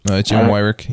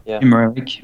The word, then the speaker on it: Weirich: Jim Weirich, yeah. – Jim Weirich, okay.